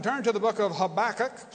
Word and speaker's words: Habakkuk: Take your Bible and turn to the book of Habakkuk. That's right, I did say that right.